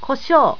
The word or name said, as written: kosho